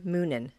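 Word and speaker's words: Muninn: But it's actually pronounced, let me think. – Munin? – Huggin and Munin.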